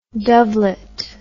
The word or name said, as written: dovelet